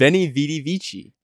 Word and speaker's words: Veni, vidi, vici: Veni, vidi, vici. – Okay, you said Italian. – But I think I don't know if the V is pronounced with the W.